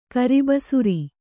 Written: ಕರ್ಬಸಿರು

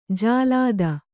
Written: ಜಲಧಿ

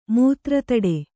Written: ಮೂತ್ರತಡೆ

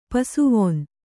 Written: ಪಸುವೊನ್

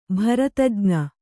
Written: ಭರತಜ್ಞ

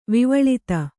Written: ವಿವಳಿತ

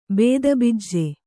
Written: ಬಿತ್ತುಬೀಜ

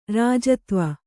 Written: ಋಜುತ್ವ

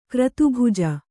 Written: ಕ್ರತುಭುಜ

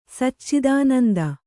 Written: ಸಚ್ಚಿದಾನಂದ

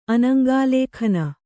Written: ಅನಂಗಾಲೇಖನ

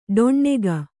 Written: ಡೊಣ್ಣೆಗ

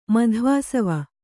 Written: ಮಧ್ವಾಸವ

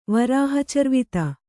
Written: ವರಾಹಚರ್ವಿತ

